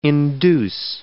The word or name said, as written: induce